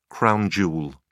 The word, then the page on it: crown jewel: British English